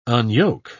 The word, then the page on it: unyoke: British English